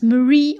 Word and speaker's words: Marie: Hank wird zu Hause zu Marie sagen, Hank, Marie und Walter, die Namen werden englisch ausgesprochen. Es ist Marie und Walter.